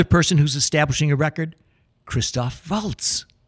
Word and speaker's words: Christoph Waltz: To go back, Christoph Waltz. – Oh, Christoph Waltz. – Right. – Yes, we must pronounce the name accurately.